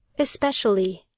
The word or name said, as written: especially